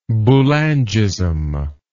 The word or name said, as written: Boulangist